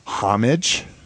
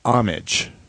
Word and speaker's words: homage: So NPR has used two pronunciations in the last few years. They have used homage, so H-O-M-I-J, and they have used homage.